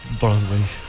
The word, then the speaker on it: Broadway: ¿Se pronuncia así? – Broadway. – Bueno, usted pronuncia mejor que yo. – No, pero lo pronunciaste excelente, Sandrín.